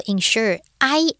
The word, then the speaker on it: insure: 话，这件事情就可以 ensure 确保 all day comfort 整天舒适这件事情就可以发生。当然，这个 ensure 跟其他的这个动词。也有一点点这个相关，为什么呢？因为同样的 insure，i